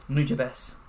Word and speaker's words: Nujabes: Nujabes. – Nujabes. Okay, Nujabes. I thought it was. – Yeah. So yeah, I was just gonna say like, ooh, I wanted to make sure it wasn't Nujabes.